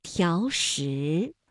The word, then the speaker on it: 条石: 那本来两个相连呢还应该是两个三五啊，但是呢考虑到连读需要变调，那么第一个字就读成了三四，第二个依旧读三五，比如条石。